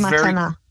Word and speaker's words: Matana: Matana? Is that how it's pronounced? – Yes. – It was Matana. – Matana. Okay. I'm awful with. – No, it's a Hebrew word.